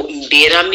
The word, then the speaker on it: mbira: mbira music, which is spelled M-B-I-R-A.